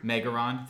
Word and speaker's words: Megaron: There's a, I believe you used the term Megatron. Did I say that correctly? – Megaron. – Thank you. – Megaron.